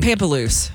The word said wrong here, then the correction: Pamplemousse: I would have gotten that Pamplemousse.